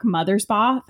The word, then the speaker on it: Mothersbaugh: But um, the music in this movie and Drop Dead Gorgeous were both done by Mark Mothersbaugh.